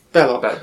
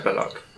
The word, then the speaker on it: Belloc: Is this Melloc? – Belloc. – Belloc.